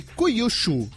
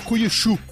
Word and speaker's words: Kyushu: Pesquisadores da Universidade de Kyushu, no Japão, eu provavelmente pronunciei errado, né? Deve ser Kyushu.